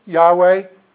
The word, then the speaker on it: Yahweh: Yahweh is what we think it should be pronounced like.